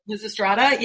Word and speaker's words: Lysistrata: You wrote something about the Lysistrata. Am I pronouncing that correctly? – Lysistrata, yeah.